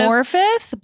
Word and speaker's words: Morfydd: one of the stars, oh boy, Moy Firth Clark reports, One Moifer. Oh, I'm really sorry if you hear this. – Morfydd, but it's spelled M-O-R-F-Y-D-D.